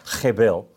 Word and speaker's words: hebel: It's the Hebrew word hebel, or however, that's how you say it with an Aussie accent, hebel.